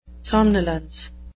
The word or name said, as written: somnolence